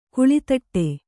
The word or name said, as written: ಕೋಲ್ತೊಟ್ಟಿ